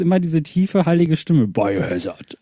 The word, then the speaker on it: Biohazard: Du hast es nicht ganz richtig ausgesprochen. Man sagt nämlich Biohazard.